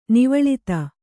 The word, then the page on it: ನೌವಾಲಾದಿ: nauvālādi